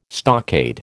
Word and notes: stockade: American English

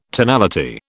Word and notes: tonality: British English

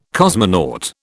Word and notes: cosmonaut: British English

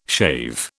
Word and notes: shave: British English

